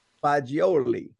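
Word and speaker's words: Faggioli: And I know, so Massimo Fagiolo, what's the pronunciation? – Fagioli.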